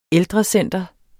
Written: ældrecenter

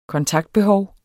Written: kontaktbehov

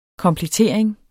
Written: komplettering

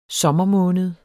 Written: sommermåned

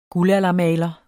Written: guldaldermaler

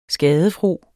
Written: skadefro

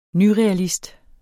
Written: nyrealist

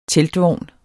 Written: teltvogn

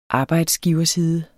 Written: arbejdsgiverside

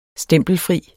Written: stempelfri

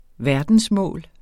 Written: verdensmål

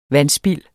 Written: vandspild

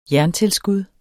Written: jerntilskud

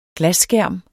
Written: glasskærm

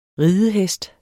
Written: ridehest

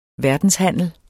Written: verdenshandel

